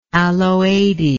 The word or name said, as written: aloadae